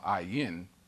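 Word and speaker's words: Ayin (עין): And first of all, Ayin is the 16th letter of the Hebrew alphabet, and it's a silent letter, and it has no translation of it in English. There's no English equivalent to this letter. And it's a letter, it's amazing. It's a silent letter, but it's always associated with some vowel.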